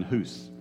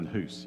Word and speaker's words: Hus: His name rhymes with goose. And Gary insists on pronouncing it incorrectly every time. John Hus. John Hus, yes.